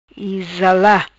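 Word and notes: Izala: Patuá

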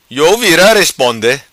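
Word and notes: Vira Iou Responde: patua

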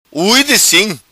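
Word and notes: Ui Di Sim: Macanese Patuá

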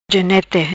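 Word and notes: Genete: patua

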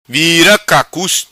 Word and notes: Vira Cacus: patua

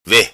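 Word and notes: Ve: patua

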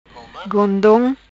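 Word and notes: Gondom: patua

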